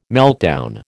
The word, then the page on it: meltdown: American English